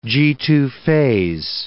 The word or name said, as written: Ga2a phase